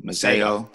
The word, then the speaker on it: Masego: He just dropped a song called, well, him and Brass Tracks, and I can't pronounce the other guy's name, but it's M-A-S-E-G-O, Masego. Masego, man.